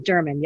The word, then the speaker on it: German: Katrina, simple, German. Yeah, just like the nationality.